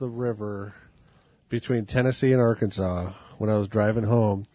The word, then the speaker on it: Arkansas: Is it pronounced Arkansas or Arkansas? Yes, Arkansas.